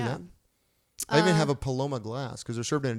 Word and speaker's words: Paloma: Paloma.